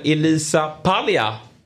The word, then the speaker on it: Elisa Paglia: Vi säger varmt välkommen till fotbollsmorgon, Elisa Paglia. – God morgon, hej. – Uttalade jag ditt namn rätt där? – Ja, det gjorde du faktiskt.